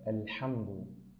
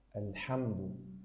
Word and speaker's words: الحمد: ورد إلينا نطقها بهذه الطريقة: الحاء، الحمد، الحمد، الرحمن، الرحيم، الرحيم، ولا تقول الرحيم الرحيم الرحيم، لا الرحيم.